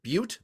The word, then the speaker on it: Butte: An elephant escaped from a traveling circus in Montana and went wandering through traffic in Butte. That is the correct pronunciation of that town.